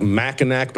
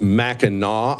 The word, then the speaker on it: Mackinac: It looks like Mackinac, but I think it's pronounced Mackinac Island.